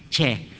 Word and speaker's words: Chia: I had one student, his family name was Chia, C-H-I-A, Chia. Her father being a Cantonese, how do you pronounce Chia in Cantonese? Chair. And the nurse wrote Chair, you know. C-H-A-I-R, that was his surname.